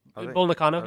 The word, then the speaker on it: Bull Nakano: Bull Nakano, yeah.